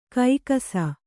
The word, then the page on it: ಕೈಕಸ: kaikasa